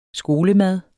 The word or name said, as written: skolemad